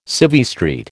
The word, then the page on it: Civvy Street: American English